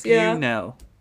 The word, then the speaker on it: Buesnel: Buesnel.